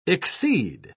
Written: exceed

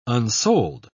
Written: unsold

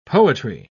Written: poetry

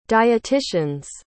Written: dietitian's

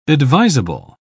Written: advisable

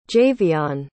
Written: javion

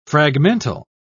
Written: fragmental

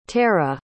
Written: tarrah

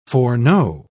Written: foreknow

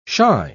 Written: shy

